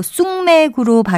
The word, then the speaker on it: 숙맥: 그리고 쑥맥으로 발음하는 경우가 많아서 첫 음절의 초성을 쌍시옷으로 쓴다고 생각하기 쉽지만 쑥맥, 시옷을 쓴다는 것도 기억하시면 좋겠습니다.